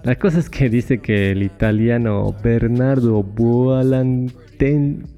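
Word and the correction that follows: Buontalenti: Pucha, ¿cómo se pronuncia? Bernardo, ya eso está fácil. Buon Talenti, ahí está, Buon, em, que era arquitecto, escenógrafo e ingeniero militar.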